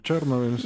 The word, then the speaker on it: Chernobyl: ¿cómo se pronuncia? Chernobyl, Chernobyl. Chernobyl, sí.